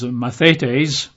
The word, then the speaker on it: mathetes: Now, the Greek word for disciples is a Greek word, we'd pronounce it probably as mathetes, which if you're going to spell that in English and taking notes would be M-A-T-H, as mathematics, M-A-T-H-E-T-E-S, mathetes.